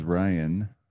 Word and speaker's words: Ryan: And this is Ryan, R-Y-A-N, not R-E-N.